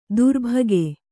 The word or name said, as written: ತರ್ಬುಗ